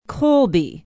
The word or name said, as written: colby